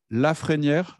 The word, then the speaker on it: Lafrenière: Aujourd'hui, c'est Daniel Lafrenière, je prononce bien ton nom. Oui, Lafrenière, oui, tout à fait.